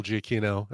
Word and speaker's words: Giacchino: Is it Giacchino or not Giacchino? Yeah. Or? – No, you just said it right.